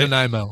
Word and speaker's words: Nanaimo: Nanaimo.